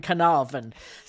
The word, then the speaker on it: Carnarvon: realised there's actually an R in Carnarvon.